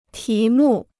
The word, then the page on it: 题目: tí mù